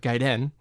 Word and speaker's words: Gaiden: We're trying to be correct to the source material, which is the Japanese word Gaiden, and yeah. – say it right.